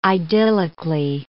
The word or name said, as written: idyllically